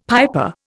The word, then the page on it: piper: Indian